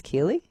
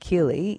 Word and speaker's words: Kirli: They're led by a proud Gunai woman called, I think it's pronounced Kirli. It's K-I-R-L-I.